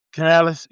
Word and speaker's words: Canales: Canales, yeah.